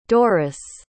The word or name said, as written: Doris